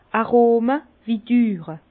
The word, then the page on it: Arome wu düre: Bas-Rhin; Herrlisheim